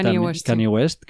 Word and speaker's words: Kanye West: Pero que Kanye West,